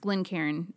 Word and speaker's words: Glencairn: And that is spelled GlenCairn. So it's pronounced Glen Karen.